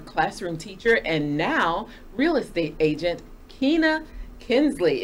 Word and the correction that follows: Knisley: Is it Kinsley? – It's Knisley. – Knisley. You know, the silent K just kind of throws you. – Yes. – Kina Knisley, thank you so much for joining us at Crossroads in Learning.